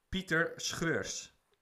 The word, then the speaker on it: Schreurs: I should pronounce this, I think, because it's like Dutch. So, Peter Schreurs.